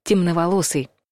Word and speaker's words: темноволосый: Темноволосые нужно писать слитно и через букву «Е», а не любимую нами «Ё». То есть произносить нужно тоже через «Е». Темноволосый.